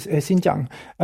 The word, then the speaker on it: Xinjiang: Xinjiang.